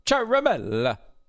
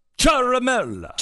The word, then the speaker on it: Ciaramella: A lot of people are saying Ciaramella. It's Ciaramella. – Ciaramella. – Ciaramella. Because it's Italian. – Yeah, okay. – And the emphasis should be on the third syllable. So Ciaramella.